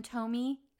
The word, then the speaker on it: Thome: I say it right? Jim Thome? yes.